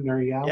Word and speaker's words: Nir Eyal: Is that how you say his name, Nir Eyal? – Yeah.